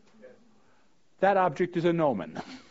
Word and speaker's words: gnomon: G-N-O-M-O-N. Does anybody here know what the English word G-N-O-M-O-N means? It's pronounced gnomon.